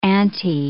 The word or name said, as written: ante